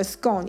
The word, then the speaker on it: scone: scone 一样，它的它的押韵的对象是 bone 骨头那个 b o n e，可是更多的人，百分之五十四趴的人，s c o n e 的念法是 scone。